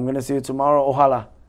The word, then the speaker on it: Ojalá: When we want to say God willing, Inshallah, we say Ojalá. – I mean, that sounds like Allah in there. – Yes. – The creator. – Yes, Ojalá.